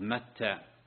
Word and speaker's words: متى: يونس ابن متى، هكذا تلفظ متى بوزن شتى.